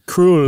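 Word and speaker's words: Krøll: That O has a null set. – It's Danish. It, so yeah, some Danish pronunciation. – Kroll.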